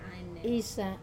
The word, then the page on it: sa: wamesa